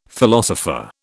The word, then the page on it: philosopher: British English